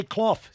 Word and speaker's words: Clough: So it's definitely Clough, C L O U G H. – Yeah, Clough. Yeah, Love it's hard it. to uh, pronounce it for Aussies, I think.